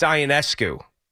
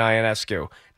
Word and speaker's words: Ionescu: It's Ionescu. That's how you pronounce the last name of Sabrina Ionescu.